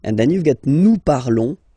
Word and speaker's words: parlons: Classical because you will see that with the other groups as well, it's quite common to have this O-N-S for nous, okay? Nous parlons, okay? Remember, final S is not pronounced, okay? And then this O N together, they will give you the sound on, on, okay?